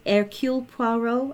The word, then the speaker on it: Hercule Poirot: Yes, one is Hercule Poirot. – Mm-hmm. – And I can never say his... Hercule Poirot, I think yes.